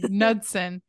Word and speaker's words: Knudsen: The K is silent on that for sure. – Okay, Knudsen, who goes by Victor Surge on the internet for a contest on the website Something Awful.